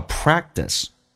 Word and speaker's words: practice: 那么比较省力，同时 practice 的可是不用发音，因为两个辅音在美式发音只用发后面一个，而且那个 t 要浊化成 d, 叫 practice, go practice your English first.